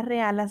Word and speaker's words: reala: También esta palabra reala significa admitir rebaño ajeno en ganado propio. Reala es una palabra llana, no se acentúa en la penúltima sílaba debido a que ésta termina en vocal.